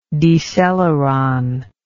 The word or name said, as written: deceleron